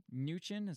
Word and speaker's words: Mnuchin: Mnuchin, is that how you pronounce that? – Yes.